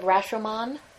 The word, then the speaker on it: Rashomon: And they were in a movie together, that horrible American Western version of Rashomon.